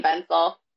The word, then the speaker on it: Venzel: It's just at Caroline Venzel. – Okay. – No spaces. And pencil is, or it's, Venzel is spelled like pencil, but with a V.